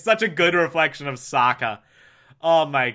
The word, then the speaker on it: Sokka: That's right, I'm Sokka. It's pronounced with an akka.